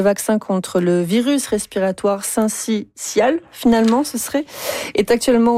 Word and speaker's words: syncytial: Non, selon le dictionnaire Usito, on prononce le syncytial. – Voilà.